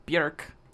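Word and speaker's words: Björk: It's actually pronounced Bjork. – Oh, like actually? – Yeah, it rhymes with jerk.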